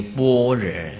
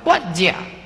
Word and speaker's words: 般若: 其实这个般若啊，梵音叫做般若，般若啊，也不是我们中文念的那个般若，应该念成台湾话那个般若才对的。